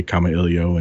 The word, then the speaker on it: Kamailio: And so, you know, I'll say Kamailio, and that's the way I'll choose to to refer to it.